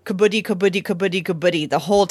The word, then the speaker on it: kabaddi: It's K-A-B-A-D-D-I, kabaddi.